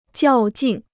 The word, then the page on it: 较劲: jiào jìn